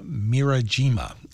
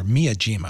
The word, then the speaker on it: Miyajima: And that's the orchestra that's led by Migiwa Mirajima, or Miyajima, I think is the correct pronunciation.